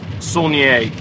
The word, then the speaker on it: Saulnier: It's, you know, it's spelled Saulnier, which is how I had said it in my head the first couple times I had seen his movies, and you know, I had to look up because I knew it was probably of French origin, and I was saying it wrong. So, according to Wikipedia, it's Saulnier.